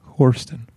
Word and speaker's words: Hourston: That's right, Hourston. – Hourston. She taught it to us today. – It's Houston with an R.